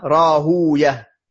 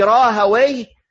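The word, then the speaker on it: Rahawayh: Il y a deux manières de le prononcer, parce que c'est d'origine perse. Ibn Rahouya, comme Ibn Majah, Sibawi, Sibouya, tous ces prénoms-là, c'est d'origine perse. Donc, ou bien on le prononce comme à son origine, cette manière-là, Rahouya, ça c'est la prononciation perse. Ou bien on l'arabise, comme ça a été fait, Sibawi au lieu de Sibouya, et Rahouya au lieu de Rahouya. Quand on dit Rahaway, c'est la manière arabisée de son nom.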